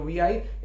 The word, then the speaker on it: Vi: And Abhijit told me that when the company rebranded itself into VI, it's actually not VI, it's V. It's pronounced as V.